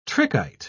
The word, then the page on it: trichite: British English